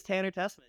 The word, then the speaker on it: Tanner Tessman: Is it Tanner Tessman? – It is Tanner Tessman.